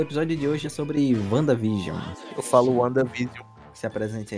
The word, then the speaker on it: WandaVision: Só que aí, tipo, ele junta tudo aí fica Wanda e WandaVision. Porque na pronúncia, quando tu fala rápido, sai WandaVision e não Wanda and Vision. Não tem um N, é tudo junto.